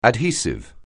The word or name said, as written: adhesive